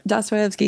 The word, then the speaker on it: Dostoevsky: So then does that include, I'm going to butcher the name, Dostoevsky? – Yes, Dostoevsky, yeah.